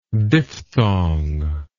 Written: diphthongous

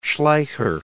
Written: Schleicher

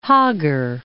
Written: Hoggar Mountains

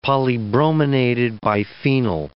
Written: polybrominated biphenyl

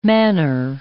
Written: manor

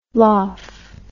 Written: loph

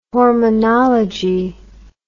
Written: hormonology

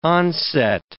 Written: onset

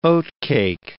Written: oatcake

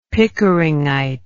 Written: pickeringite

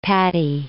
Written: Patti

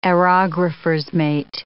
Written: aerographer's mate

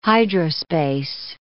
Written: hydrospace

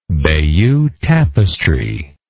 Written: Bayeux tapestry

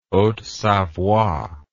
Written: haute savoie

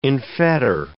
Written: enfetter

